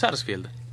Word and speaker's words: Sarsfield: Vélez Sarsfield. – Pronuncia esa S.